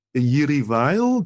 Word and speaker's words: Jiří Weil: Mendelssohn is on the Roof by Jiří Weil.